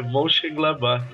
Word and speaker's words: Mönchengladbach: É, Mönchengladbach, tá certo. ah, cara, esse alemão que põe o D e não pronuncia, me incomoda.